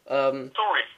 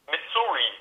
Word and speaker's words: Missouri: Missouri spricht man so aus. Missouri spricht man das aus.